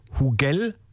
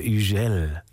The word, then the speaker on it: Hugel: Die Franzosen, die sprechen das H ja nicht, ne? Also Hugel heißt der DJ, wenn ich es deutsch aussprechen würde. Aber weil er Franzose ist, heißt er Hugel.